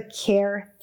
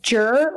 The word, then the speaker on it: Kjer: And they wrote about one of your hauntings called, and I'm probably going to get the name wrong, but I want to say it's the Care Theater. And it's spelled K-J-E-R. Care, Care. I was going to call it the Jur or the Kajur.